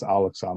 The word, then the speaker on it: Al-Aqsa: And then on the last Friday of Ramadan, the 7th of May, thousands of Arabs showed up at, how do you pronounce this? The Al-Aqsa Mosque. Thank you. Yes, Al-Aqsa Mosque.